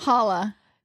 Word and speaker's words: challah: Hala. – She's like, I have some challah that I want to bring.